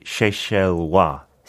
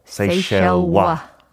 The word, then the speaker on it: Seychellois: British, and if you're from the Seychelles, you say Seychellois. Seychellois. Seychellois. – 네, 스펠링은 조금 프랑스식이니까 i 끝나지만 네.